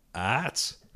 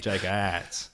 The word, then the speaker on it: Jake Atz: How do you say it? – Arts. It's double A-R-T. – Yeah, Arts. – Arts. It's got a double A. – Arts. – No. – Like aardvark. – It's a silent A.